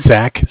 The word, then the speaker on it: Zach: So my name is Zach, spelled Z-A-C-H.